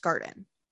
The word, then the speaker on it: Garton: It's spelled Garton, G-A-R-T-O-N, but it's pronounced Garden.